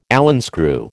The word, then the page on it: Allen screw: American English